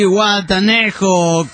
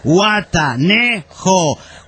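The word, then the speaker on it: Zihuatanejo: Llega el austral radicado en Canadá, mexicano, el hombre chilango en las venas, dice, a ver pirata, pronuncia conmigo, sí, guatanejo, cuauhtémoc, y tilsalclip, anticutli, dice, ahora, a ver, sí, guatanejo, cu, au, te, moc, la otra que se vaya a la burger, dice.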